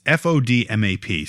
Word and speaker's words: FODMAP: It's FODMAP, so it's like FODMAP. – DMAP, I guess, yeah. – So it basically – it's like food map missing an O.